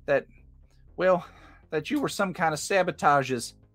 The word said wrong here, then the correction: saboteurs: Like saboteurs? – It's the one. That's how you say it.